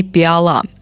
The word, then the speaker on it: пиала: Формально правильным считается только произношение предложение «пиала». Рифмуем и запоминаем. Вот тебе, Маша, пиала, чтобы из нее ты чай пила.